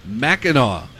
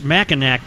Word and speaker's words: Mackinac: Well, it's Mackinac, but I think it's pronounced Mackinac.